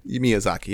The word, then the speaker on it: Miyazaki: Miyazaki, right? – Miyazaki, yep.